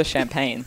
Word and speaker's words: champagne: Oh, how do you say it? You said you'd tell us. – Oh, champagne. – Champagne. Okay. – It is. I know you told me I sounded Italian, but that's how French Julian said it.